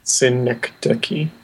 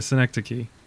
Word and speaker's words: Synecdoche: And we'd also just talked about am I saying that right? Synecdoche. Okay. – Synecdoche. – Yeah, Synecdoche. Kind of sounds like Schenectady.